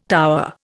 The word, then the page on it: tower: Indian